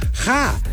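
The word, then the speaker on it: ja (ห้า): El número 5 se pronuncia ja en tailandés.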